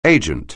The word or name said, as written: agent